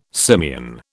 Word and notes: simian: British English

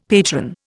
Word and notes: patron: Indian

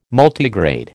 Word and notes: Multigrade: American English

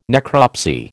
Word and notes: necropsy: American English

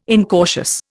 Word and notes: incautious: Indian

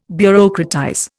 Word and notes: bureaucratize: Indian

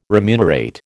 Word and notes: remunerate: American English

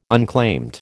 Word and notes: unclaimed: American English

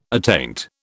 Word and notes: attaint: British English

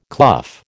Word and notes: clough: American English

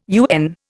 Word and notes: UN: Indian

